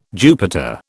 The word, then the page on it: Jupiter: British English